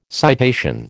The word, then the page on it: citation: American English